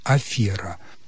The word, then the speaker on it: афера: Никогда не было нормативным произношения афера, только афера. Поэтому убеждение в том, что раньше правильно было афера, это просто заблуждение. К сожалению, бывают и такие вещи. Повторяю, правильно и было, и остается только афера.